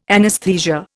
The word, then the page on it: anesthesia: Indian